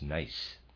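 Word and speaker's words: gneiss: Readers aside, I have looked up the word G-N-E-I-S-S. I've been pronouncing it Nice. I apologize, the correct pronunciation is Nice.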